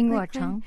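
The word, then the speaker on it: Ying Ruocheng: Her forthcoming book, Voices Carry, is the autobiography of the late Chinese actor and culture minister Ying Rocheng, if I said that correctly. – Ying Rocheng. Yes.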